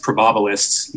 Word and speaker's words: probabilists: literally the best probabilists, and that is how you pronounce it, the best probabilists in the world were there studying the highest forms of probability possible.